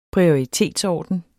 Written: prioritetsorden

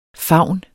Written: favne

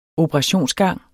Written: operationsgang

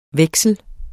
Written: veksel

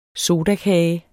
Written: sodakage

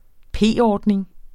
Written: p-ordning

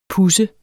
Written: puds

